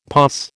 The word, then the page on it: POS: American English